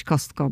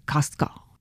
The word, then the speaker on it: Costco: I no ta amerykańska wymowa jest właśnie taka bardziej kostko. Nie o, tylko bardziej a i to drugie T gdzieś tam ginie.